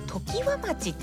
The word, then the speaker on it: 常葉町: 先ほどね、今日は何の日のコーナーで、手塚治虫さんらが提唱した、ね、牛の日っていうのはですね、田村市までは読めたんですけど、その後時和町っていうんですね、常設展示とかの常っていう字と葉っぱの葉で、常葉町って読むのかなと思ったら時和町ってね読むそうです。大変失礼いたしました。